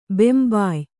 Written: ಬೆಂಬಾಯ್

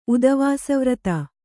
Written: ಉದವಾಸವ್ರತ

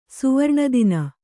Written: ಸುವರ್ಣದಿನ

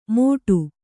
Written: ಮೋಟು

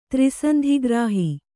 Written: ತ್ರಿಸಂಧಿಗ್ರಾಹಿ

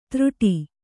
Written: ತ್ರುಟಿ

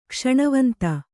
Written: ಕ್ಷಣವಂತ

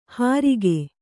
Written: ಹರಿಗೆ

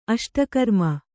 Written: ಅಷ್ಟಕರ್ಮ

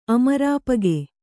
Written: ಅಮರಾಪಗೆ

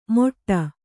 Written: ಮೊಟ್ಟ

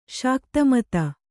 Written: ಶಾಕ್ತಮತ

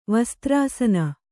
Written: ವಸ್ತ್ರಾಸನ

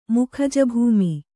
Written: ಮುಖಜಭೂಮಿ

